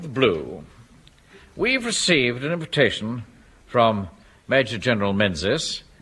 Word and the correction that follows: Menzies: Mangies. – Yeah, I beg your pardon? – It's pronounced Mangies.